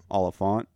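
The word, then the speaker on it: Olyphant: Oliphant. – Like elephant, but with an O.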